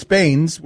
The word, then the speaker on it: Baines: You know, it's like you're going from Drummond to Bane. – Well, it's not just that, though. I'm saying it's Baines with an S.